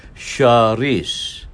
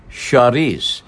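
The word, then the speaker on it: cerise: That color pink in France is pronounced charisse. It's actually the color of cherries in France. It's pronounced charisse.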